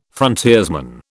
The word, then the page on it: frontiersman: British English